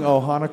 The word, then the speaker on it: Ohana: I saying Ohana correctly? – Yes.